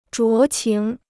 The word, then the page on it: 酌情: zhuó qíng